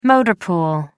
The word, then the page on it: motor pool: American English